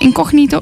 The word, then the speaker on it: Incognito: En toen was het van Incognito. Zeg ik dat goed? Incognito?